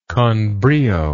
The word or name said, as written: conbrio